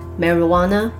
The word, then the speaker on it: marijuana: Episode Thirty Six: Marijuana Economy in Thailand.